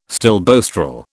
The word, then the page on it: stilboestrol: British English